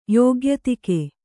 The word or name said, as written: ಯೋಗ್ಯತಿಕೆ